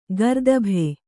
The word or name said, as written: ಗರ್ದಭೆ